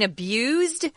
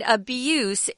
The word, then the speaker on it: abuse: If you look at it though as a verb, it's spelled the same, but we say abuse with a Z sound. Abuse is the noun form with an S sound.